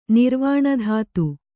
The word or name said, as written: ನಿರ್ವಾಣಧಾತು